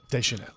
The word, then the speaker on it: Deschanel: Zoe Deschanel. Or, wait, did I say it right? – Deschanel. – Deschanel.